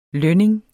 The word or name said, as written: lønning